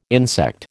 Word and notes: insect: American English

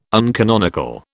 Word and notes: uncanonical: British English

